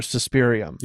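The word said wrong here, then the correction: Suspiriorum: Am I saying it right? Suspiriorium? – Suspiriorium? Yeah. – Suspiriorium. Okay. I've been saying it wrong this whole time.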